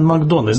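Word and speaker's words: McDonagh: Но я настаиваю, что произносить его имя нужно Мартин Макдона. Знаете почему? – Именно так, конечно, это ирландская фамилия. – Я уточняю. уточнил у своей парикмахерши, которая ирландка из Ирландии, настоящая. Я ее спросил, как правильно произносить. Она сказала «Макдона» и посмотрела на таким знаменитым ирландским взглядом. – Все, будем говорить «Макдона».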